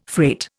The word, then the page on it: freight: Indian